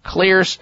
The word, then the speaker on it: Xlear: Clear is spelled X-L-E-A-R. The X stands for xylitol.